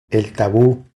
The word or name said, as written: El tabu